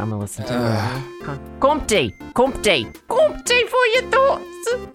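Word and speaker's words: Comté: I think someone told me it's Compte. Compte. I think it is two syllables.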